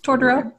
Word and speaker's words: Totoro: My favorite animated film is maybe Totoro. Am I saying that right? – Mm-hmm. Totoro, yes.